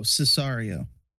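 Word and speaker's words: Sicario: I can't remember how to pronounce it. Sicard? It's like that war. It's like that war film. I'll pull it up for you so you can see it. I think that's how you pronounce it. – Oh, Cesario. – Is it Cesario? Oh. Oh, I was reading the O as a D.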